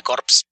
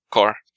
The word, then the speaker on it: Corps: Ah, y otra cosa, no se dice corpse. – ¿Cómo se dice? Core. – No, pues, porque es una palabra que viene del francés. – Ok. – Sí, si lo mencionas como corpse, parece que estás hablando del cadáver y eso ya lo vimos hace mucho.